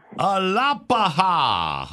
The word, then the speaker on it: Alapaha: And we got Alapahaha, Georgia. – It's pronounced hey. – Alapaha. Hey, am I close with Alapaha? Is that right? – Alapaha. – Alapaha. All right. – You got it. Nice. Alapaha.